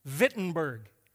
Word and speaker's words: Wittenberg: And he took it to the church in, it looks like it's spelled Wittenberg, but it's, they pronounced it with a V. They were German. Wittenberg and he did what they did.